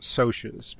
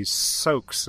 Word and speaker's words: Socs: I know it's short for social or socialite, and so I thought it would be Soch, but when you say it plural, I couldn't figure out how to pluralize it, because it's spelled S-O-C-S, so I would say Soaks in my head. – Yeah. – But I'm going to adopt Sochs, because I think that is better.